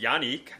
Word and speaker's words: Yannick: It's pronounced Yannick. Never knew that. – I looked that up, too. – Yeah, I always thought it was Yannick, but no, it's Yannick.